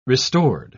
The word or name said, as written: restored